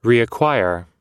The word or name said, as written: reacquire